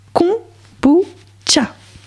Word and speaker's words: kombucha: Ils disent prononcer kombucha. – Tcha.